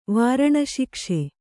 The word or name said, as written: ವಾರಣಶಿಕ್ಷೆ